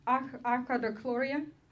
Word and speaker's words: achlorhydria: Yes, so I have achlorhydri, how is it pronounced? Achlorhydria. It's called achlorhydria, no hydrochloric acid, just water.